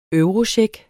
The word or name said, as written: eurocheck